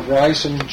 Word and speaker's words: Rysum: But he came from the little town of Reisum, Germany, which is spelled R-Y-S-U-M.